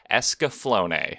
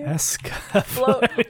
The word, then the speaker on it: Escaflowne: I think it's spelled like it sounds. Escaflowne. – Flowne? – Escaflowne.